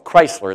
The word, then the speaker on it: Kreisler: Many years ago, there was a man by the name of Chrysler. It's not spelled like the car. It's spelled K-R-E-I-S-L-E-R.